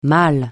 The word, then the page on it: mâle: francais